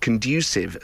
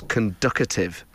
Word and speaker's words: conducive: Joe says, my partner and his mum used to pronounce the word conducive as conducative. Oh, come on. There's not even a T in there.